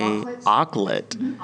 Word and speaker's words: auklet: How do you pronounce that? A-U-K? – Auklet. – An auklet, okay.